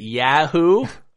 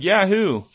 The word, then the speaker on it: Yahoo: Rhymes with achoo. – Is it Yahoo? – Yahoo.